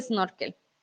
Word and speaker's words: snorkel: Y recuerden, la S al principio, nosotros no decimos sino e, e, snorkel, porque es más fácil de, pronunciar.